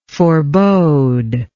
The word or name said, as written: foreboder